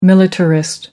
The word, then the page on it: militarist: American English